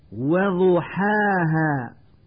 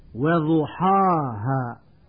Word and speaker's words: وضحاها: وضحاها، ويقول من يخطئ وضحاها بتفخيم الحاء والهاء،